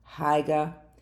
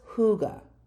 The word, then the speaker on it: hygge: Now, Huga is spelled H-Y-G-G-E. So you'd think it'd be like Hyg, Hyga. Well, it's Huga is how it's pronounced. Like H-U-G-A-H, Huga.